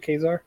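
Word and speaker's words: Ka-Zar: Is that how you say it, Kazar? – Yeah.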